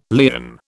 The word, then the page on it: lien: British English